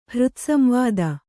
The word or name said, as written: ಹೃತ್ಸಂವಾದ